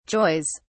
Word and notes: joys: British English

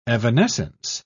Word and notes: evanescence: British English

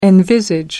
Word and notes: envisage: American English

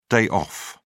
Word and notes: day off: British English

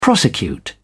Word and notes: prosecute: British English

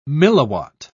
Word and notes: milliwatt: British English

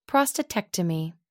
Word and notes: prostatectomy: American English

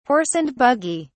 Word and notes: horse and buggy: British English

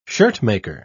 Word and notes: shirtmaker: British English

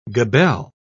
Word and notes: gabelle: British English

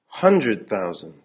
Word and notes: hundred thousand: American English

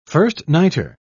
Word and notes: first-nighter: British English

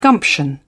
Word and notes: gumption: British English

